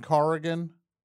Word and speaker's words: Corrigan: Am I joined by Kevin Corrigan? – Corrigan, yes.